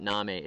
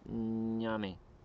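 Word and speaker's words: ñame: Somebody says I'm saying it wrong. It's not name, it's nyame. Nyame. So nyame yams. It looks like name, N-A-M-E.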